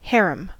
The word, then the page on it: harem: American English